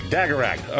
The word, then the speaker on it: Dagarag: Dagarag, all right.